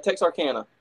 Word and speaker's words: Texarkana: It happened at a Monday night, a show on Monday night in Texarkana. Am I saying that right? In Texarkana? – Texarkana. – Texarkana, Arkansas. So, it's nothing too serious.